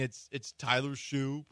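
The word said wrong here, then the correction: Shough: probably. – Shuck. – Exactly. – Doesn't matter. – Exactly. – It rhymes with suck.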